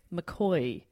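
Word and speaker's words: McCaughey: And the director of the NGV, a guy called Patrick McCoy, said, "This face is going to haunt Melbourne for the next 100 years.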